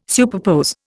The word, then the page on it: superpose: Indian